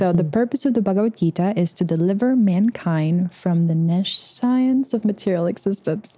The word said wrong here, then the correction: nescience: Nescience of material existence.